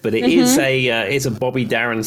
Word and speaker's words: Darin: I don't know if I'm saying his name right, but I do know the song. It's Mac the Knife, but it's Bobby Darin. Darin, is that right? – Yeah, Bobby Darin.